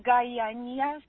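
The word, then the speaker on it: Gayana: My name is Guyana. G-A, it's spelled differently sometimes. Y-A-N-A. – Yeah. – Diana. – Hi, Diana. – Yeah, in English transliteration, but my true name is Guyana, so Gaia.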